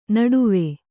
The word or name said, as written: ನಡುವು